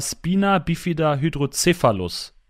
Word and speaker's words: Spina bifida hydrocephalus: Ähm, und du hast seit Geburt einen Gendefekt, äh, deswegen sitzt du in einem Schlitten, und zwar Spina bifida hydrocephalus. Ist das richtig ausgesprochen? – Ja, das ist korrekt ausgesprochen, ja.